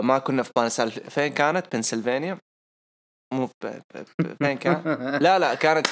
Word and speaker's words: بنسلفانيا: انطقها زين بنسلفانيا.